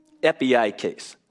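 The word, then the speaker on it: epieikes (ἐπιεικής): The Greek word for reasonableness there is pronounced epieikes.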